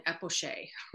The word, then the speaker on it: epoché: So one of the pieces of that is you have to do this thing called it's spelled E-P-O-C-H-E. And I was pronouncing it epoch for 3 years, probably with my advisor, with my chair and with my committee, and nobody corrected me. – Oh, wow. – And then I was watching a video about doing an epoché.